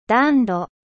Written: cheminee

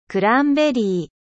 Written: canneberge

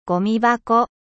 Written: poubelle

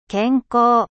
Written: 健康

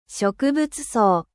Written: flore

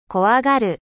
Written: 怖がる

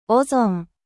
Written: ozone

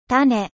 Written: graine

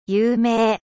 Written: 有名

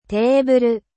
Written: テーブル

